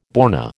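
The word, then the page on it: borné: American English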